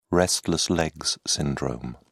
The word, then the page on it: restless legs syndrome: British English